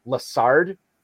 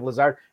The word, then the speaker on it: Lazard: And he, when he talks about Alan Lazard, he always pronounces his last name Lassard instead of Lazard.